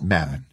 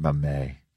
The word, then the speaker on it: Mamet: Mamet, it's Mamet. – It's Mamet. – Not Mamet. – Soft T.